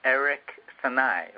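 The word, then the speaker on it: Eric Sanae: Yes, and your Facebook is Eric Sanai, right? – Yes. – And that's spelled E-R-I-C, like Eric, and then the second name is S-A-N-A-E.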